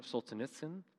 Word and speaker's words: Solzhenitsyn: And what's interesting about Solzhenitsyn,